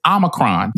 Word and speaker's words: Omicron: Y'all are not saying it correctly. It is Omicron. The long O in Greek is Omega. Omicron, Omega. Get it right or pay the price.